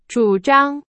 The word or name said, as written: 主张